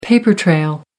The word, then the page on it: paper trail: American English